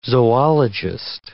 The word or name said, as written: zoologist